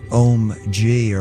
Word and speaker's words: Ohm-G: And before that, Om G, or I think that's how it's said, spelled OHM-G.